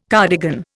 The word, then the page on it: cardigan: Indian